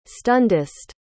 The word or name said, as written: stundist